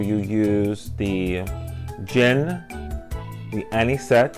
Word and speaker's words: Anisette: Anisette, yes.